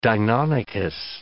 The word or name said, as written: deinonychus